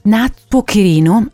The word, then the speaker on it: Natsuo Kirino: Assolutamente da leggere, Le quattro casalinghe di Tokyo, scritto da Natsuo Kirino, avrò detto giusto? – Giustissimo!